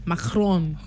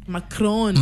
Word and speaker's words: Macron: Macron. – Okay, kwa nini macaroni? – Macron.